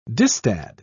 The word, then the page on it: distad: British English